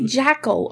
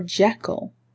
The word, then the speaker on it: Jekyll: Do you say Jackal or Jekyll? Jekyll. It's actually an E.